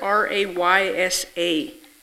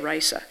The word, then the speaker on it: Raysa: You spell that R-A-Y-S-A. It would look like Ray-sa to us, but you say it Risa.